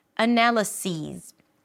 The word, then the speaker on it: analyses: And analyses rhymes with these analyses, these analyses, right?